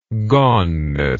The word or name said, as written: goneness